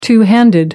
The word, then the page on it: two handed: American English